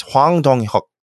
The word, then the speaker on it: Hwang Dong-hyuk: I was reading the English romanization, which is never accurate. Sorry. It's Hwang Dong-hyuk. So that's how you pronounce it.